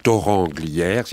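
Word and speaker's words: Thorens-Glières: Alors à Torrent-Glières, ça s'écrit Torrens mais ça se prononce Torrent-Glières, c'est à côté d'Annecy, à une vingtaine de minutes, je crois, 20 minutes, une demi-heure d'Annecy.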